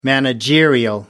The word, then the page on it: managerial: American English